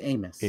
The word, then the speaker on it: Amos: Amos, I don't remember how they pronounce it. – It's Amos.